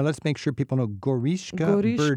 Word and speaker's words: Goriška Brda: G-O-R-I-S-K-A, yes. – And then B-R-D-A. Now, I was taught you have to have a vowel, and this is B-R-D-A, and it's two syllables. Where's the vowel? – We tend to be a little lazy with those. – So, Brda, B-R-D-A, Goriška Brda, and it's just a beautiful, it's also another wine-growing region.